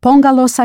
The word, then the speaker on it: póngalos: Yes, póngalos aquí. Note the stress and accent on the first syllable.